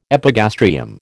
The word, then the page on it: epigastrium: American English